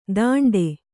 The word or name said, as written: ದಂಡೆ